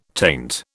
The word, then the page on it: taint: British English